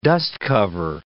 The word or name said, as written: dust cover